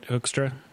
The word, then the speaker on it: Hoekstra: Jason Hoekstra, that's how you say it, right? Hoekstra? – Yes.